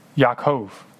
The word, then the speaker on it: Yaakov: Jacob's name in the Hebrew is pronounced Yaakov. This is what it looks like, Yaakov.